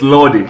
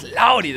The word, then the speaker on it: Lordy: Tell me how you want me to pronounce it, because it's a tasty word. Lawdy. – So it's Lordy. – It's like Lordy.